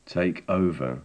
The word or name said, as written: take over